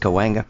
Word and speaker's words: Cahuenga: Everyone knows in L.A. it's pronounced Cahuenga.